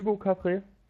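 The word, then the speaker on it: Hugo Cabret: Heißt eigentlich Hugo Cabaret. Also, ist mir egal. – Da wird auch im Film so direkt ausgesprochen, Hugo Cabaret.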